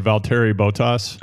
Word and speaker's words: Valtteri Bottas: Spencer, you're deeper into F1 than me, so correct my pronunciation here if it's wrong, but Valtteri Bottas. – Oh, I was just going to bring this up. I think you pretty much nailed it, but he has his own gravel race in Finland.